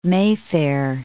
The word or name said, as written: Mayfair